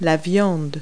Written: meat

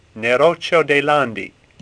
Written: Neroccio

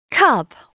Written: cub

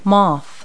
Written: moth

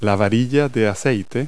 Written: dipstick

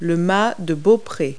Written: bowsprit

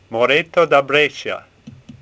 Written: Moretto

